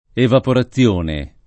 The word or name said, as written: evaporazione